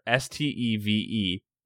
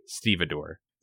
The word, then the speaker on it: stevedore: You brought this up before, but I think you called it a Stubidor. – Well, it's spelled fucking weird like that. Stubidor. – Oh, I've heard of Stubidore. – Hold on, let me look it up. It's spelled Stevedore. Stevedore. Stevedore.